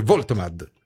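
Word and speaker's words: Woltemade: Si scrive Voltemad, si legge Voitemad.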